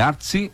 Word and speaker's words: Artsy: É dois locais, é importante, vai lá no Instagram, Artsy, que é A-R-T-S-Y. Tá? É Artsy, é a pronúncia essa? – Isso, não, Artsy.